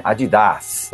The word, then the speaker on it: Adidas: E como é uma empresa alemã, na verdade, a pronúncia correta do nome é Adidas. Mas, obviamente, a gente vai continuar falando Adidas mesmo, né? – não vou mudar mesmo, né?